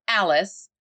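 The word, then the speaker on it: Alis: Yeah, it's A-L-I-S, Alice. – Alice. – And that's exactly how it's pronounced.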